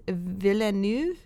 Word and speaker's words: Villeneuve: So directed by Denis Villeneuve. Is that how you say his name? – Yeah, you nailed it.